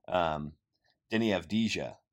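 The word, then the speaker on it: Deni Avdija: I guess the guys that you kind of hear some stuff about from time to time is LaMelo Ball, Denny Avdijila. I'm butchering his name. I should have pulled that up before I attempted it. Denny Avdija. There we go.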